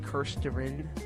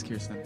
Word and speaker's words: Kirsten: I'm going to be reading it, all credit to Kristen Weir. Hopefully I'm pronouncing that right. – It's Kirsten Weir. – Oh, yeah, it is Kirsten. Not Kristen.